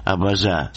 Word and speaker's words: абаза: «Абаза». Сейчас его выпуском занимается генеральный секретарь этой ассоциации, известный абхазский поэт и общественно-политический деятель Геннадий Аламия. Что тут скажешь? На самом деле слово «абаза» с ударением на последнем слоге,